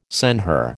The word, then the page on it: senhor: American English